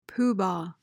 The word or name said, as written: pooh-bah